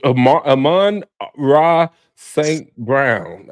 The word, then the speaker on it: Amon-Ra St. Brown: What about your boy Amara Saint Amon-Ra St. Brown.